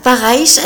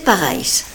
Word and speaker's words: Paraïs: Manosque, le 18 janvier 2019, dans la maison de Jean Giono, de l'écrivain Jean Giono, euh, le Paraïs, je le prononce peut-être pas très bien. – c'est tout à fait ça. Paraïs avec un tréma sur le i, c'est Paraïs.